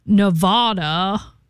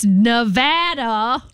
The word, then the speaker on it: Nevada: You pronounce Nevada. It's Nevada. – No, it is. – Since I grew up there, it drives us crazy.